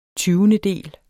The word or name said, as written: tyvendedel